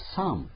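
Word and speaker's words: Psalm: 英語ではサームですね。 P を読まないと。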